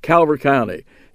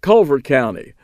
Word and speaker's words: Calvert: Louis was comptroller of Maryland and an unapologetic, unrepentant booster of Calvert County. He pronounced it Culver County, where he was born and raised and developed his political muscle.